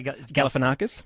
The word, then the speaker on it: Galifianakis: Zach, how do you say Galifianakis? – Galifianakis is the director, friend of Michael Keaton.